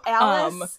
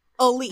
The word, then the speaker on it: Alyce: So Alice is, I can't read my note. – It's going to drive me insane that her her name is spelled A-L-Y-C-E, but it's pronounced Elise.